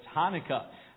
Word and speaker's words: Hanukkah: The Feast of, it's spelled kind of funny, sounds like it says start with a cha, that's Hanukkah.